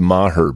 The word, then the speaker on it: Maher: And it's pronounced Maher, Bill. Get it right.